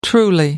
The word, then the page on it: truly: American English